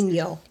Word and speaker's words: Guadagnino: The director, by the way, is Luca Guadagnino. How do we pronounce this? – Guadagnino. – Guadagnino. Guadagnino. Mm-hmm. Italian.